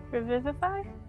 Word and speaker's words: Revivify: Revivify? – Yeah. – Did I say it right? – Yeah.